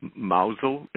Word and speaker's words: Mousehole: We lived in a village called Mousel. It's spelled Mousehole.